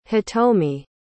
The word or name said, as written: hitomi